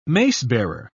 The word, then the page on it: macebearer: British English